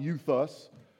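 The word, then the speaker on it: euthus (εὐθύς): It's pronounced euthus, and that means immediately.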